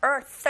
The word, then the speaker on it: earth: 맨 끝에 단어 earth 하실 때 th 발음 th 잡아주시면 되고요.